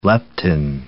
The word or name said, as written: leptin